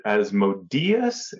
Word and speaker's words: Asmodeus: Is it Asmodeus? Yeah. I'm not sure how you pronounce it. The accent's on the E.